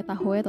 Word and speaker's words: Tahoe: Cuman kayak perjalanan kantor gitu sekitar 400 kilo ke Danau Tahoe. Gue gak tau tuh ngomongnya Tahoe atau Tahu ya. Tahoe. Gue kira okay.